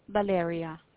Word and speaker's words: Valeria: Valeria. – Can you spell it? – V-A-L-E-R-I-A.